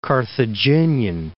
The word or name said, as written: Carthaginian